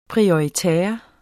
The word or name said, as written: prioritaire